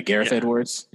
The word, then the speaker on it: Gareth Edwards: But give me Garth Edwards for the director. If I'm saying his name right. The guy did Rogue One. – Oh, Gareth. Yeah, Gareth Edwards.